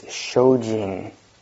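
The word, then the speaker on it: shōjin (精進): And the Japanese pronunciation of this is shōjin.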